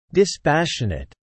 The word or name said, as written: dispassionate